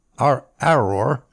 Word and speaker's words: Aroer: I'm assuming it's the city of Aroer, A-R-O-E-R. It's hard to pronounce. Aroer.